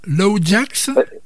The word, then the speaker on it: LOADJAXX: C'est comme ça qu'on prononce LOADJAXX? – Oui.